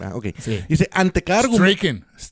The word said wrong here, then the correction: streaking: ¿Cómo se lee esto? Ah, streaking. Streaking, así se le dice.